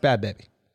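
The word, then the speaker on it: Bhad Bhabie: Bobby without the R. Bad. – It's pronounced Bad Baby.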